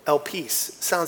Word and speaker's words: elpis: Now, the word hope actually is the Greek word elpis. It's interesting that the word hope actually sounds like it has the word peace in it. Elpis is how it's pronunciated. It's the Greek word for hope, which means confident expectation.